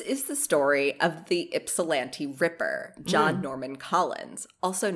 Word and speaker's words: Ypsilanti: It's apparently pronounced Ypsilanti? – Oh, there you go. – Spelled with a Y, so you knew I was already, like, my brain was already panicking from that, so.